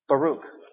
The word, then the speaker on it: Baruch: We find the next character here, and his name is actually Baruch.